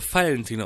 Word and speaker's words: Valentin: In Bayern wird der Name Valentin ausgesprochen. Mit Vogel-V.